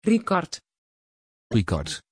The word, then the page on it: Ricard: Dutch